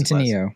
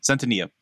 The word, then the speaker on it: Centineo: And I do think that while people give Noah, is it Cento, or how do you pronounce Centineo. – It? Centineo.